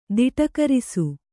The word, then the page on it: ತುಟುಕರಿಸು: tuṭukarisu